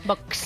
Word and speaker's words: Vox: Parece, oye, pero es de los pocos que pronuncia muy bien Vox. – Vox. – Claro, será porque es su jefe de prensa y sabe que hay que pronunciarlo con cierto vos.